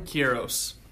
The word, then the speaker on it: Queiroz: Queiroz.